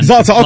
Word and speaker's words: Zlatan: Help me out. Zoltan. – Yeah, I used to call him Zoltan. – Zlatan. Okay. Zlatan. Even the beef between, you can tell those two guys do not like each other.